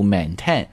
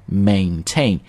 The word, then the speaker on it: maintain: 注意在读这个音的时候不要读 maintain 啊，是 a 音 maintain，两个音都是这个 a sound 啊，具体这个发音我们在这就不展开了，大家可以订购我们在喜马拉雅上的暴虐晨读版啊，我们会讲得很细的。他们声称啊，或者说他们坚持 there was no malicious intent，在这里正好学两个词。